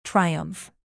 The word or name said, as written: triumph